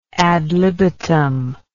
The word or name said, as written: ad libitum